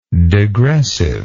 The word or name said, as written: degressively